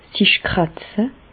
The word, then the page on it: sich kràtze: Bas-Rhin; Reichshoffen